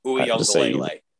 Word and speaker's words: Uiagalelei: That's Is that how you pronounce that? I honestly haven't heard it. – Uiagalelei.